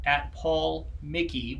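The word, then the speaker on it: PaulMichie: So that's @PaulMickey, which is spelled M-I-C-H-I-E. It's Scottish. The CH is a K sound, and it's got like Loch Ness Monster.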